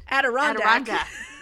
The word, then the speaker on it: Adirondack: Adirondack. – I can't see the word. – I know. She had been born somewhere in the Adirondack Mountains, which I don't know where those are.